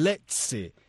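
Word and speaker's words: Lecce: Y cuando es doble C, ellos entonces hacen con el leche. Pero bueno, no soy italiano. – No, yo creo que se pronuncia como CH. – Leche. – No. Leche. Así. – El leche. Sí, pero con acento italiano.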